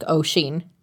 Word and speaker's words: Oisín: For the sake of this post, let's say they named him Oisín, which is an Irish name that is meant to be pronounced like Oisín, as I just said. In accordance with the old Gaelic tradition of nothing being pronounced like it's spelled.